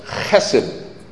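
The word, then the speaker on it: Hesed (חסד): So here's how you pronounce Hesed. It's Hesed.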